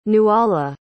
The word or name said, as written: nuala